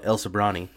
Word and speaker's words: Sobrante: Like, if you live in, like, El Sobrante, which is, like, a Mexican, like, very... Sobrante is, like, a very, like, hard-to-pronounce Spanish word. All white people live there, and they call it El Sobrani.